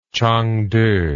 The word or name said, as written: Changde